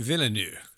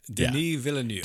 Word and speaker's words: Villeneuve: Villeneuve? What is it? – Villeneuve. – Yeah. – Denis Villeneuve. – Denis. Thank you very much.